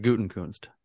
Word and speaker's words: Gutenkunst: No, he pronounces it Gutenkunst. – Who's he? – I don't think Brian. He does, because there's no N there, and his father, John Gutenkunst, that's how he pronounced it. – Okay. – It was the Gophers head coach. – There's not an N in there? – No, there's an N in Nst, but not after Gute.